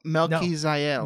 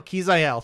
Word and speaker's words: Melquizael: Melquizael. No, Melquizael. Thank you.